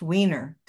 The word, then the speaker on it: Weiner: Well, I think, Emily, it started out with being born with the last name being pronounced Wiener, W-E-I-N-E-R. And, you know, I became desensitized early to jokes about male anatomy and the Oscar Mayer jingle and all of that. So when I went off to college, I changed the pronunciation to Weiner, but it already had its effect.